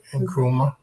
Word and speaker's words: Nkrumah: Nkusi, I'm not saying his name right. – Nkrumah.